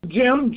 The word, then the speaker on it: Jim: First name is Jim, J-I-M.